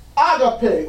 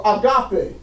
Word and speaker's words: agape: Then there is the agape or agape. Depends on who pronounces it.